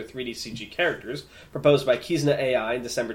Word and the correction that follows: Kizuna: That's just Kizuna AI. To VTuber. I am a Kizuna AI fan. Leave me alone. – Well, you said it wrong, so I just corrected you.